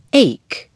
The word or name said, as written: ache